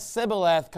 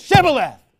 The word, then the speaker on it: Shibboleth: I'm not going to let Sibboleth come into the church. It's Shibboleth. And we're going to pronounce it right.